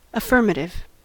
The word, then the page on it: affirmative: American English